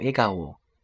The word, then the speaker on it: 笑顔: 笑脸呢，写作汉字笑颜，读作 A 嘎我 A 嘎我。那你在平时，比如说夸别人小孩子笑脸很可爱的时候，就可以这样说：この子の笑顔が可愛いですね。可能国的 A 嘎我嘎卡哇伊迪斯内，就是这孩子笑得很可爱。